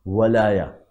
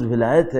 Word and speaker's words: ولایہ: وہ واو پر زبر کے ساتھ آیا ولایہ, چونکہ اردو میں تلفظ ولایت ہے اس لیے میں اس کو ولایت عرض کر رہا ہوں ورنہ ولایہ آیا ہے عربی کے اندر.